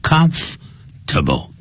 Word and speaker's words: comfortable: 本来念慢的时候叫做 temperament，可是念快的时候 temperament 变成三个音节 temperament Temperament 这样念，好，就不要念成 temperament，好，记住啊，就像 comfortable 舒适的，念快的时候就变成 comfortable，三个音节 comfortable 这样念的。